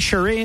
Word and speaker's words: Cherie: Am I saying your name right, Cherie? It doesn't sound like I'm saying it right. – That's right.